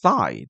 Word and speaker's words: thigh: 大概会用一个字叫做 thigh， T-H-I-G-H T-H-I-G-H，舌头伸出去，然后 gh 是没有发音的，就变成 thigh，那个 i 是 i 哦，那个 i 就念 i 哦，就变成 chicken thigh。